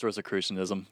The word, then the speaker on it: Rosicrucianism: Yeah, it's Rosicrucianism.